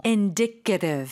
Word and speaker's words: indicative: 그리고 쌤 지금 발음을 들어보니까 indicate 이건 동사는 indicate인데 얘는 좀 달랐어요. – Indicative. – Indicative. – Indicative. – 액센트도 그렇고 발음 자체가 좀 다르네요.